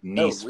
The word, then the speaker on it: Nice: And it was actually pronounced French, like Mr. Nice, like Nice, France. Yeah. Like you're going to Nice.